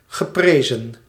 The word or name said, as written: geprezen